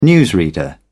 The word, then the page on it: newsreader: British English